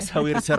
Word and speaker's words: Saoirse: Mbak Saoirse Ronan gitu. Padahal ternyata dibacanya Saoirse Ronan.